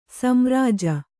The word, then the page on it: ಸೋಮರಾಜಿ: kannada